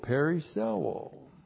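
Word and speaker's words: perisseuō: It's also periselwo. Looks like that in the Greek, P-E-R-I-S-S-E-U, long O, periselwo.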